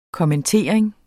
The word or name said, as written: kommentering